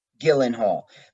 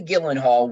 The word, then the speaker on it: Gyllenhaal: Gyllenhaal. Maggie Gyllenhaal was paid bucks.